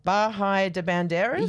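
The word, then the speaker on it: Bahía de Banderas: Bahia de Banderas? – Yes, you said it right.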